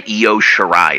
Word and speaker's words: Io Shirai: I'm going to fucking say her name right. I know somebody fucked it up and they just went with it for forever now. But her name is goddamn Io Shirai. It's not that hard.